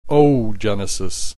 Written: oogenesis

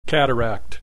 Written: cataract

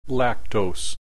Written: lactose

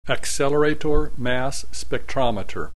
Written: accelerator mass spectrometer

